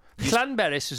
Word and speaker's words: Llanberis: I Llanberis is very good.